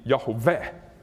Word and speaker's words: Yahweh: The Lord, Yahweh, if you want to pronounce it wrongly, of Israel." By the way, it probably should be Yahweh. Three syllables. Accent on the last one.